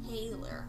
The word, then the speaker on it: thaler: I think it's called, it's spelled T-H-A-L-E-R, but it's pronounced Taylor, I believe.